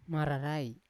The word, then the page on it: mararai: Yawa